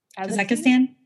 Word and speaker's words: Kazakhstan: Kazakhstan? Yes, thank you. I can't. – Kazakhstan? – Yeah. Mm-hmm. There's an H in there that should be not pronounced.